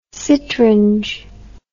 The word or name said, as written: citrange